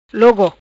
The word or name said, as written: Logo